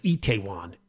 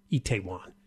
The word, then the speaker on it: Itaewon: It's pronounced Itaewon, Itaewon. So it's a popular place for nightlife, and that became the Halloween go-to place the other night.